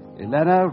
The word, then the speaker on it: Elena: Elena rhymes with Vienna.